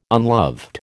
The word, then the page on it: unloved: American English